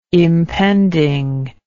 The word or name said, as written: impending